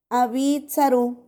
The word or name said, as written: 2abitsaru